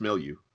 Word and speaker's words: milieu: And I mean, you talk about using, like, this Greek mythology milieu. I'm sure you mispronounced that, French listeners. – No, it's milieu. – Milieu, okay. I was only raised learning Spanish and English, and I never took French.